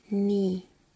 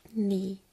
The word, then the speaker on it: knee: It is called knee in German. Knie is very easy to pronounce here, but knee is very difficult to pronounce here, you know? Knee. Knee. Knee. Knee is much more easy.